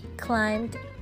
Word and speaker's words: climb: He climbed a little higher climbed。这个 climb 要注意 b 不发音啊，爬了有一点高。